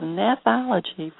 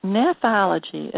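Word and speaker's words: gnathology: the 70s, while teaching at the USC School of Dentistry, his practice was revolutionized when he learned the principles of nephology from his mentor.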